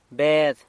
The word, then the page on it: Bed: sanlucasquiavini